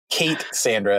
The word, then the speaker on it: K8ssandra: Yeah, I know we got clever inside, you know, and it's funny how this has been the problem, but it's K8ssandra, as in Kate, the name Sandra.